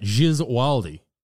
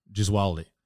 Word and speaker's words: Gesualdi: How to say it, Gesualdi. – Sounds like jizz. – No, I didn't spell it with a J, I spelled it with a G.